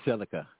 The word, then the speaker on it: silica: So, in relation to Sagittarius, the cell salts in the zodiac, okay, it's Cilicia, cell salt number 12, or maybe I'm pronouncing it wrong, silica, Cilicia? – It's silica, silica. – Okay, uh-huh. – It's silica, okay, silica.